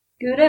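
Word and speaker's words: Geuda: Gouda and Dun. – That's right. Gouda and Dun. – Gouda like the cheese, but not spelled like the cheese.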